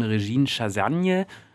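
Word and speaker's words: Régine Chassagne: Das sind in erster Linie Win Butler und seine Gattin, inzwischen Regine Chassagne. Habe ich das richtig ausgesprochen? – Ich glaube ja. Lasagne.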